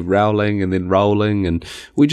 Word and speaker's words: Rowling: True or false, J.K. Rowling's last name rhymes with bowling? True, because this is a big dispute, you know.